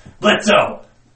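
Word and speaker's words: Blitzø: Blitz! Blitzo! Where the O is silent.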